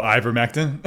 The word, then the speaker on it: ivermectin: How do you say it? – Which one? – Ectomectin. – Oh, ivermectin.